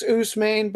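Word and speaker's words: Ousmane: Osman Dane. – I think it's Usman, but I'm right there with you. – Usman Dane, small forward from France.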